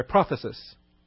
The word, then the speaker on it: prothesis: All right, our next expression here that's spelled out is prognosko, or no, I'm sorry, prophesis.